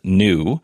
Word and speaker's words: NXU: Atlas Motor Vehicles, which is a company that's just down the road from me in Mesa, they have changed their name to NXU, which is actually pronounced new.